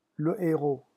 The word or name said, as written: le heros